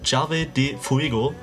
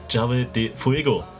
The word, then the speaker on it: Llave de Fuego: Ich glaube, Llave de Fuego. – Bingo! – Sehr gut, eingeben und schon seid ihr auf der Homepage von Llave de Fuego.